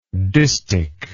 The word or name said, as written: distichal